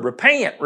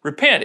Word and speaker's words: repent: I'd hear the word repent, repent, repent, like it had three syllables instead of two, repent. No, it's repent.